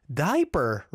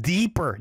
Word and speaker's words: Deeper: I think it's pronounced deeper, not diaper.